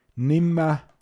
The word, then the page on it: a nixnutzega Loda: Pinzgauer Mundart